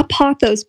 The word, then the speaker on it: pothos: And then my other recommendation would be a pothos plant. – A pothos what? Or pothos? – Pothos. P-O-T-H-O-S. – Oh, okay. I'm I thought maybe you were pronouncing pathos in a weird way. Like pathos.